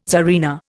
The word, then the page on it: tsarina: Indian